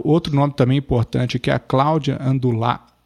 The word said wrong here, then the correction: Andujar: Andujar, desculpe a pronúncia.